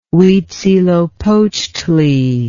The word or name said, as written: Huitzilopochtli